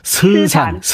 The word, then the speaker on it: 서산: 서산은 그 발음을 그렇게 하시면 안 돼요. 스산, 스산.